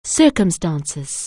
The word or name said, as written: circumstances